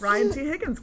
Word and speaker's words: Ryan T. Higgins: By Ryan T. Higgins. Wait, did I say it right? – You did say it right. Nice job. – Yes. – Ryan T. Higgins.